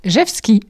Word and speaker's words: Rzewski: Alors, son nom de famille, c'est R-Z-E-W-S-K-I. Et ça se prononce Jevski. – Tout bêtement.